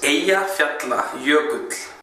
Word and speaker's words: Eyjafjallajökull: I wanted to address the issue that is the pronunciation of the Icelandic volcano, so they can at least try to pronounce it right. – Hm. So here it goes. It's Eyjafjallajökull.